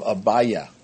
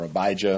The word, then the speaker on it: Abijah: of the course of Abiah, or Abijah, if you want to pronounce it that way, Abiah.